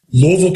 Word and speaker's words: Louisville: Louisville, Kentucky. – So we would not say it the way you said it, which is the correct way to say it, but we wouldn't expect it any other way.